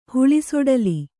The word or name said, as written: ಹುಳಿಸೊಡಲಿ